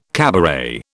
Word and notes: cabaret: British English

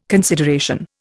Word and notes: consideration: Indian

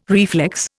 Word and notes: reflex: Indian

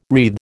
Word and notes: wreathe: American English

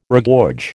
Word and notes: regorge: American English

